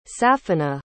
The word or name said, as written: safina